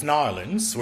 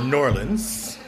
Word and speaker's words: New Orleans: So before I left, I pronounced it as New Orleans. It was a very formal in pronunciation. When I got there, I heard it was Narlins or Norlins. After a couple of cocktails, it became Narlins.